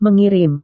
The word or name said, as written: mengirim